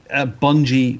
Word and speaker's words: Bungie: For me, Bungie rhymes with spongy, so that's the main thing for me.